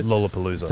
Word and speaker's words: Lollapalooza: Lollapalooza concert. – Lollapalooza.